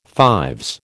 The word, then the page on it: fives: American English